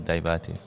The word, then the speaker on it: Diabate: A-H-M-E-D for Ahmed. – Mm-hmm. – And then D-I-A-B-A-T-E for Daivate.